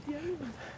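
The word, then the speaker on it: Jelling: Gelling. Dad, yelling. It's yelling, Dad. – Stop. What do you mean? It's gelling. It's pronounced, it's got a J on it. – But that's not normal. You say Y for Germanic languages.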